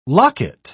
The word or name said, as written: locket